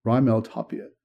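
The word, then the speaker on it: Raimel Tapia: But I checked the pronunciation guide on Baseball Reference. RYE capitalized, Rymel Tapia. Rymel Tapia.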